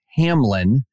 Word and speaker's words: Hamline: University, whatever it is, but I know it's pronounced Hamline, which is where a professor was fired after showing an image of Muhammad painted by a Muslim in medieval times.